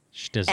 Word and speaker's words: Shtisel: And it's spelled S-H-T-I-S-E-L, if you're looking to search for it. Shtizzle. – And if you can say it correctly, which Daddy and I can't seem to say it quite right three times in a row, more power to you.